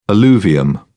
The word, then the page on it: alluvium: British English